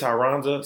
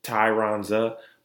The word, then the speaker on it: Tyronza: I'll be like, my name is Tyronza, so it's Tyronza, but you can just call me Ty.